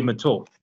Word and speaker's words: Mitul: Like everything was like everything was a fire sale, and you and how do you pronounce his name? Mitul Patel. – Yeah, Mitul. So yeah, Mitul Patel.